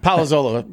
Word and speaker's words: Palazzolo: Palazzolo. Yeah, there's an extra. – I so respect my mess of his name. Sorry, Steve. – They throw the syllables at you. It's an extra syllable.